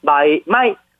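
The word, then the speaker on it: 마이 마이 (买卖): 예, 오늘은 그 매매하다, 매매하다라는 것인데 우리 한자 매매할 때 한자를 그대로 중국 간자체로 써서 하는데 발음도 비슷합니다. 마이 마이 마이 마이 삼성과 자성의 그 마이 마이 하면 매매하다라는 것입니다.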